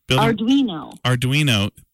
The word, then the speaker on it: Arduino: Camp 3, I believe, building motion sensors with, I don't even know how to say this last name. Can you say this? – Arduino. – Building motion sensors with Arduino.